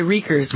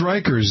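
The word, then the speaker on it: Rekers: This is about a guy named, and I'm not even sure how to pronounce his last name. It's R-E-K-E-R-S. – I'd say Reekers. – I'm going to say Reekers. George Reekers, according to Frank Rich in the New York Times yesterday, now 5 seconds into Monday we are.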